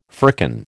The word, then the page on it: firkin: American English